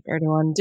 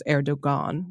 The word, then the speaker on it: Erdoğan: which is a great victory for everyone who didn't want people to use the hard G, because the alternative was that he was Erdogan, which would have been, of course, inaccurate.